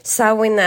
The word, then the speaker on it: Samhain: Uttalet Samhain och varför jag säger det nu är för att annars så skriver massa av er och hör av er och berättar hur man uttalar det. Samhain är det keltiska uttalet.